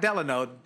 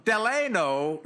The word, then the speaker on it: Delano: His name is Ambassador, I got to say it correctly, Ambassador Delano, yeah, not Delano, Delano Lewis.